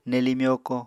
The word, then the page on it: nelii myokó: Apatani